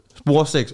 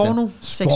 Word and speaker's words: spornoseksuel: ham der, den øh, sporseks, hvordan var det nu udtalt? – Spornoseksuel.